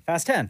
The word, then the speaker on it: Fast X: So the new Fast and the Furious movie, how do you say it? Fast X or Fast 10? – Fast 10.